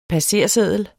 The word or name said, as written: passerseddel